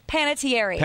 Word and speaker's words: Panettiere: How do you say that? – Panettiere. – Panettiere.